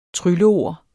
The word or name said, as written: trylleord